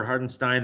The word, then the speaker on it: Hartenstein: So what did you think of the move for Hartenstein? They obviously get another, you know, a pair of second-round picks. What? Did I say it wrong again? – No, you said it right.